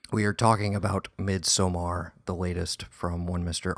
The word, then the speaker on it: Midsommar: How do you say it, buddy? – Midsommar. – Gotta hit the R.